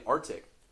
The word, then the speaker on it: RTIC: One of them split off and got bought out and took the same technology and went and made Arctic. It's spelled RTIC.